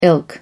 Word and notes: ilk: American English

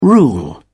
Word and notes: rule: British English

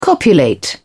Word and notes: copulate: British English

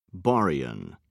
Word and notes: baryon: British English

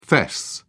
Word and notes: fess up: British English

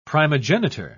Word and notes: primogenitor: British English

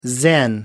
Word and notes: zen: American English

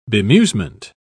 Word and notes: bemusement: British English

